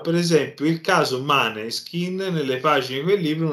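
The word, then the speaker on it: Måneskin: E quindi e eh, devo dire ora, per esempio, questo discorso di parlare dei maneskin, moneskin poi in realtà sarebbe la, la pronuncia più, più mh, mm, corretta,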